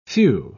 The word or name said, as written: phew